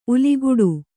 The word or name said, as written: ಉಲಿಗುಡು